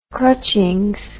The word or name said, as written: crutchings